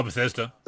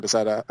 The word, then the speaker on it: Bethesda: Oh, Bethesda. – Yeah, Bethesda.